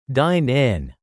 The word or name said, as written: dine-in